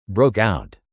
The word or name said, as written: broke out